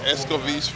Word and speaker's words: escovitch: Okay, the other one is our fried fish, the escovitch fish, that's the way we pronounce it.